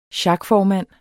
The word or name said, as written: sjakformand